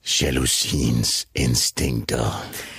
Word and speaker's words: Jalousiens instinkter: Men jeg tror, det skal udtales Jalousiens instinkter.